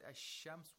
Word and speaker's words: الشمس: The word الشَّمْس, we have a sun letter in the word shams. So we have to pronounce the sheen with tashdeed without pronouncing the lam sakina.